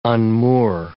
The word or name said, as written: unmoor